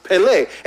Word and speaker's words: pele: but in the Hebrew is pronounced pele, and it means wonderful, wondrous, and marvelous.